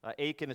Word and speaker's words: Aitken: Aiken is spelled A-I-T-K-E-N. Jonathan Aiken's biography, it's titled John Newton, From Disgrace to Amazing Grace.